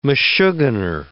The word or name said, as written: meshuggener